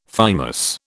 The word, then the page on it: thymus: British English